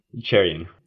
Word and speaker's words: Cherian: Cherian.